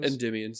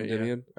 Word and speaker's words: Endymion: Is that what we agreed his name is pronounced as? Endymion sounds right. Endymion.